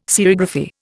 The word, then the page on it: serigraphy: Indian